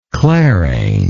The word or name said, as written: clarain